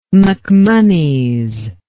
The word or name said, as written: macmonnies